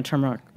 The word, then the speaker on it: turmeric: you pronounced the R, and just like the person at the cafe who was like, oh, like, I don't know. They didn't even understand you. You know, like, you obviously right. speak, like, perfect plain English. Like, you were born in this country. Mm-hmm. And just because you pronounced, I mean, just say it, turmeric. Yeah. Like, no one had heard the R in that word before, and they couldn't even understand what you were saying.